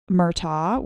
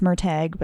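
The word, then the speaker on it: Murtagh: I also read Murtaugh, which technically it's Murtag, but I refuse to pronounce it that way.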